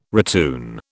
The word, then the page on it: retune: British English